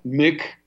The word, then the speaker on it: MIC: Now, I guess I would call it the military intelligence corporatocracy complex, MIC, with a hard C, because you get two of them.